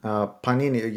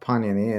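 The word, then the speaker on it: Pāṇini: It was fascinating that you mentioned Panini. You said that we put the stress on the first syllable, right?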